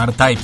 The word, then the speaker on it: R-Type: Pero qué bien pronunciado ahora el año, R-Type, tío.